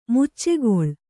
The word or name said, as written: ಮುಚ್ಚೆಗೊಳ್